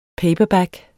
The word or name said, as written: PAPERBACKS